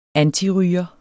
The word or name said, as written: antiryger